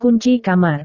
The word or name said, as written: kunci kamar